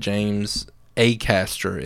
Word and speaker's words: Acaster: I've actually been told that James Acaster is his name. It's not Acaster. I thought it was Acaster, but apparently it's pronounced James Acaster, so we'll go with that moving forward.